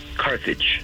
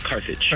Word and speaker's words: Carthage: So it's just like it's spelled. Carthage. – Carthage. – That's T-H. – What I said. – Yeah, just like it's spelled, Carthage.